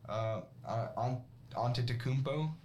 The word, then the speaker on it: Antetokounmpo: You got the Bucks that won an NBA Finals with Antetokounmpo.